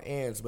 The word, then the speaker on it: Agnes: And then Ann's, it's a spot called Ann's, but it's spelled A-G-N-E-S.